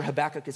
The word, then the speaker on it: Habakkuk: I have to be honest, I, am pretty sure Habakkuk is pronounced that way.